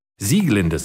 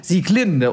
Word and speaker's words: Sieglinde: Sieglinde sehe ich hier gerade aus der Walküre, weil nämlich alles bei Wagner auf der ersten Silbe betont wird.